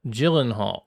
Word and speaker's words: Gyllenhaal: He says, I have to correct this, but Jake Gyllenhaal, as I was saying it last week, is pronounced Gyllenhaal, and it's not with the hard G.